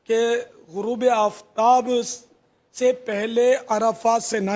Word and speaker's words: عرفات: دوسرا انہوں نے مسئلہ پوچھا عرفات سے مزدلفہ, تو اس کا وہ تلفظ عرفات نہیں ہے, عرفات عین اور را پر دونوں پر زبر ہے, عرفات اور مزدلفہ نہیں ہے, مزدلفہ لام کے نیچے زیر ہے.